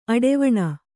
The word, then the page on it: ಅಡೆವಣ: aḍevaṇa